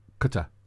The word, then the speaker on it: Qatar: Qatar.